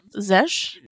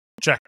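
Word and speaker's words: Zschech: How do you pronounce Zesh? I don't know how you pronounce it, actually. – Check.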